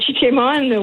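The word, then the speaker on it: Chi-Cheemaun: Est-ce que je le prononce comme il faut? – Oui, le Chichimun, oui.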